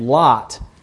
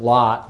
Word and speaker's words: Lot: Now, Haran, one of these men, Terah's sons, had another son named Lot, and we pronounce it Lot, but it's probably Lot, longer O sound.